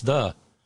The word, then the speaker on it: ye: No, it's the. That Y is actually a hard TH. The.